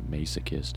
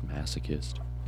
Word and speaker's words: masochist: Because masochist is not masochist. It's masochist. – And there's also a whole syllable in between mass and ist.